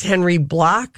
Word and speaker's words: Bloch: Also today at the age of 96, Henry Block, who co-founded H&R Block, but he spells his name B-L-O-C-H, probably the traditional German. – Yes. – And not Block. – But they thought people would mispronounce it as Block.